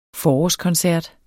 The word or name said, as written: forårskoncert